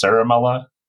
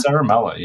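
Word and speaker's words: Ciaramella: Well, it got anglicized, so it's like everyone says Sharamala. – Sharamala? – Sharamala, yeah.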